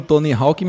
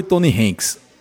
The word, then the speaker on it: Tony Hawk: Primeiro você tá falando Tony Hawk e minha cabeça tá em ar de explodir aqui, porque nunca na história das locadoras de São José de Seridó esse nome foi pronunciado. Sempre, durante décadas, décadas não, foi exagero, mas mais de 10 anos, sempre foi Tony Hanks. Tony Hanks de skate, Tony Hanks de skate.